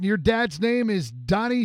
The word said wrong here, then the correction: Doyne: The pronunciation is Doyne. – Oh, it's Doyne Stash. Okay. Doyne Stash.